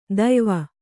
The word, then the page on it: ದೆಯ್ವ: deyva